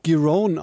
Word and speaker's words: Giron: Ja, er spricht sich Giron aus tatsächlich,